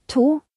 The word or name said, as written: 2